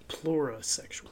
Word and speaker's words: plurisexual: pluralsexual, if I'm pronouncing that right. Monosexual... – What? Oh, pluralsexual. I wanted to see it. – Oh. Pluralsexual. – Plurisexual. – Plurisexual. There you go.